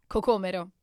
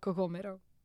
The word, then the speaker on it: cocomero: Cocomero. – Cocomero. – Or anguria. And I don't like when people call it anguria. – Why? – Because cocomero is the best word. – Cocomero. – Cocomero.